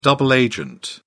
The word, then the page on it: double agent: British English